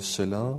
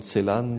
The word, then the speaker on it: Celan: Tu disais que tu aimais Paul Celan, ou Paul Celan, selon la prononciation.